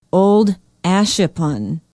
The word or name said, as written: old ashippun